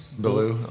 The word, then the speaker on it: Beaulieu: Yeah, how is that pronounced? Is it Beaulieu or Beaulieu? BV? – BV. – Beaulieu Vineyards? – Beaulieu, okay. – Beaulieu Vineyards. – Yeah. – Beaulieu? – Well, that's how I pronounce it. I used to sell it.